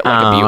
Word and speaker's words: Butte: Spelled like butt? – No, it's B-U-T-T-E. Like a Butte.